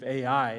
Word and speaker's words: Ai: The problem is, the very next battle that they go into is with a town by the name of Ai.